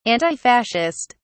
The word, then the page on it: antifascist: British English